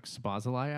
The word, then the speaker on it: Szoboszlai: And the craziest part about that is that I always say his name wrong, but I took Szoboszlai out. Actually, I said that right, Luke? – Good job there.